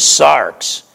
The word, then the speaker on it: sarx: The word in the Greek is sarx. We would spell it S-A-R-X.